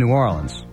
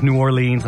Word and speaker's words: New Orleans: You know, if you are new to New Orleans, you better say it right. You know, it's New Orleans. You know, nobody says New Orleans unless they're writing a song or, you know, they want their ass kicked.